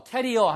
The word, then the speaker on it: career: C-A-R-E-E-R은 career, 경력이란 뜻입니다. 우리가 career, career 하는 것은 C-A-R-R-I-E-R이라고 해서 뭐 나르는 거라든가 아니면 뭐 균의 보균자라든가 예, 이런 걸 갖다가 날 것, 들 것 이런 걸 carry라고 하잖아요. carrier, 그렇죠? 그래서 그런 걸 얘기하고요. 이거는 career, 스펠링도 다르고 발음도 다릅니다. 철자도 다르고요.